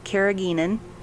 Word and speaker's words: carrageenan: So other scientific studies show sea moss may help with weight loss because of the, I'm going to say it wrong again, carrageenan. – That's right. – Carrageenan.